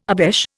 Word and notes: obeche: Indian

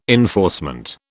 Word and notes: enforcement: British English

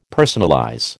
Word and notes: personalize: American English

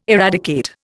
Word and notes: eradicate: Indian

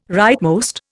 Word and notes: rightmost: Indian

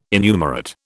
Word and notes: innumerate: British English

